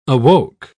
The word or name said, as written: awoke